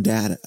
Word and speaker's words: Data: See, I say Don Data, but I spell it like Data, as the Star Trek character.